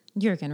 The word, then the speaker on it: Jürgen: Now, so there's this moment early in the book where Jürgen, right? Soft J? – Yeah.